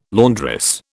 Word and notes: laundress: British English

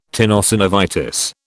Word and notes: tenosynovitis: British English

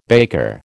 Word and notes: baker: American English

